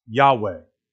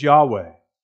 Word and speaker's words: Yahweh: His name is Yahweh, as best we understand the pronunciation. His name is Yahweh. There are four letters in the Hebrew alphabet. That's God's name, and it's Yahweh. It's not Lord.